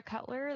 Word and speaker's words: Cutler: So my name on Facebook is just Emily Shira Cutler. That's spelled S-H-E-E-R-A. My email address is ecutler, C-U-T-L-E-R, at mail.usf.edu.